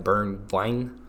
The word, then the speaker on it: Bergwijn: It's like Bergwijn, if I'm saying it right. – Bergwijn.